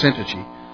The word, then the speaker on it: Syntyche: I did look up Syntyche. According to one place, it's pronounced Syntyche.